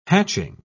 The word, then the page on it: hatching: British English